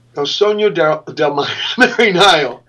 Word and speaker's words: El Sogno del Mariano: I probably mispronounced that. – El Sogno del Mariano. – Oh, fuck. I'm sorry. I took German. – El Sogno del Mariano. It's a little like Spanish. Spanish would be El Sueño del Marinero.